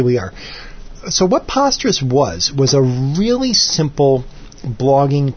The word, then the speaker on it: Posterous: This was inspired by the fact that posterous, which supposedly truly is pronounced posterous and not posterous, even though it should be posterous because you're posting things, but apparently they pronounced it posterous as in preposterous.